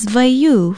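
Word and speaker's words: waiū: Aloha, I'm Nilani Poliahu of Hawaii Public Radio, and our Hawaiian word of the day is vaiu for milk. Vaiu is spelled W-A-I-U with the macron or stress mark, e kahako over the U.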